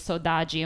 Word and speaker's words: Sodade: And so the last song was Cesaria Evora, and that song is Sodaji, and we are just speaking, she was Cape Verdean, and so the way that it's spelled is S-O-D-A-D-E, and it is true, in Portugal, the D sounds very different.